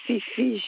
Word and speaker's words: Fifi: Je suis Madame Fifi Ghislaine. – Alors, comment ça s'écrit, s'il vous plaît? – F-I-F-I.